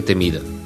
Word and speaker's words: Темида: Темида. Така, вижте как, като не сложиш грешно ударение и акцент, как се обърква цяло изречение. Започвам от начало. Капани за рушвети ще дебнат на всяка крачка Темида, предвижда още стратегията.